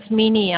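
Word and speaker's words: Menie: It's Mini. I'll spell it to you. It's very unusual. It's M-E-N-I-E. And then Theron, T-H-E-R-O-N. It's M-B, then the Theron, T-H-E-R-O-N.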